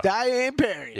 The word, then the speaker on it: Diane Parry: Dion Parry, plus 153 over Anastasia Potapova. Parry right now. Look, by the way, it's spelled Diane Parry. If you're Diane Parry. Yeah, that's right.